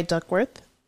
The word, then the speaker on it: Duckwrth: It's by Duckworth. It's spelled DUCKWRTH.